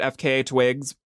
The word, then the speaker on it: FKA Twigs: I'm pretty sure you pronounce it FKA Twigs, but it means formerly known as Twigs, but everyone says FKA Twigs.